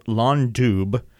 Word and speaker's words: Lon Dubh: This next one we're going to hear from Ardu is called Lon Dub, which is spelled L-O-N-D-U-B-H, which in English spells blackbird.